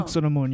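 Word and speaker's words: Anck-su-namun: It's spelled Anksunamun, but I feel like the whole time they're saying Anuksunamun. I don't know. – Anksunamun. You just say it really fast.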